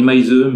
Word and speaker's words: Meise: je vais à nouveau écorcher le nom du réalisateur qui s'appelle Sebastian Meis. J'aurais dit Meise, mais. C'est un, non, c'est un S. M e I S e.